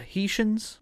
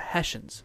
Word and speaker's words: Hessians: So I don't know if this is you pronounce it the Haitians or the Hessians. – The Hessians. – Is that how you say that? – Yes.